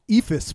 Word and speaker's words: Eephus: E-E-P-H-U-S. It takes its name from a pitch called the Ephus pitch that is the junkest of junk pitches in baseball.